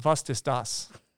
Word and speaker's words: was ist das: In Germany, it's pronounced, was ist das? Was ist das?